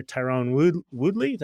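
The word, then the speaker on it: Tyron Woodley: being on the shorts originally of MMA fighter Tyrone Woodley.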